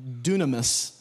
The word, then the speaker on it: dunamis: Now, again, diving a little bit deeper, ability there in the Greek is, I'm going to say this right, dunamis, all right?